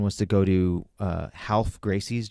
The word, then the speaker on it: Ralph: Um, uh, so it's like his, I think it's his brother Half, and it's spelt Ralph.